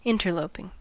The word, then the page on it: interloping: American English